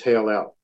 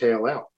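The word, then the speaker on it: Heylel: This is Hail-El. Other English translations say Daystar, but the word is Hail-El.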